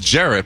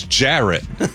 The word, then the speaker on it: Jarrett: Ferret does rhyme with Jarrett, but my name is Jarrett.